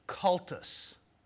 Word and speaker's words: cultus: The focus of worship in the Old Testament is on the, I'm going to say this word, then explain it to you, okay? And spell it for you. Cultus. C-U-L-T-U-S. Have you heard that word before? What word does it sound like? – Cult, yeah.